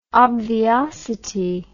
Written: obviosity

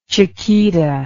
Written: chiquita

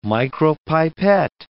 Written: micropipet

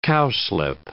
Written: cowslip

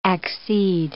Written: accede

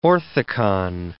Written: orthicon

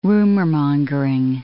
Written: rumormongering

